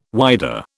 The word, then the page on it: whydah: British English